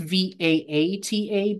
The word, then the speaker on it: Vata: So the first dosha is the Vata dosha, that's spelt Vata, but it's pronounced Vata, not Vata. It's pronounced Vaata, but it's written Vata.